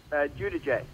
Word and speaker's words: Giudice: Did you pronounce it the Italian way, Giudice? – Giudice.